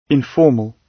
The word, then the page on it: informal: British English